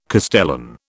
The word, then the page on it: castellan: British English